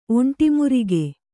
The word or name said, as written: ಒಂಟಿಮುರಿಗೆ